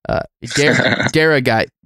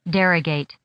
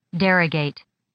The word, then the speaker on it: derogate: Okay, derogate is the correct pronunciation.